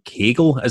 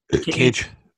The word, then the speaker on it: Cage: Westy wants to put over AEW for bringing in Brian Cagle. Is it Cagle? – Cage. – Is it how do you pronounce that? Is it Cagle? – Sorry, it's Brian Cage.